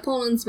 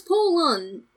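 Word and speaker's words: Pollun: I keep on hearing you say that word as Poland, like the country. – No, it's Poland. It's P-O-L-L-U-N, Poland.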